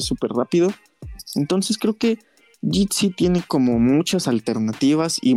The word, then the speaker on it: Jitsi: Bueno, eh, se pronuncia Jitsi y eh,